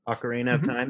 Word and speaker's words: Ocarina of Time: Did I say that right? – Ocarina of Time, yeah.